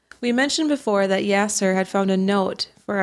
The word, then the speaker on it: Yaser: And I'm sorry that I mispronounced it. It's Yaser. – Yaser. – Not Yasir.